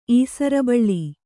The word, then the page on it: ಈಸರಬಳ್ಳಿ: Kannada